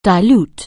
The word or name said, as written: dilute